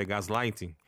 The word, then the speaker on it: gaslighting: Tem um termo, Sandra, que é, o Raul me corrige na pronúncia, é gaslighting, que tem aparecido frequentemente associado ao narcisismo.